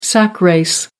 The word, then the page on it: sack race: American English